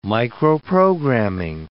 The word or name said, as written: microprogramming